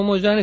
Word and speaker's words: Mo Yan: Bueno, se escribe con Y, ¿no? – Sí, Mo, M-O-Y-A-N. Perfecto. Mo Yan.